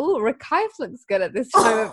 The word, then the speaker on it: Recife: So you call it Recife, how do you say it? – Yeah, Recife. Recife. Oh yeah, so that's because it's spelled like Recife, which is how I've been saying it the whole time.